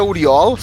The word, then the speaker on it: Oriol: Porque o meu se pronuncia Uriol, se escreve Oriol.